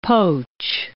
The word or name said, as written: poach